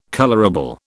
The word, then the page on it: colourable: British English